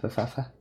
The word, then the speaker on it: Pfaff: Yeah, it's like Chris PFAFF. I don't know how to pronounce it. – Okay. – Faff? – Oh, okay. Faff? – But. Faff. Yeah. And his is called Short Story Long.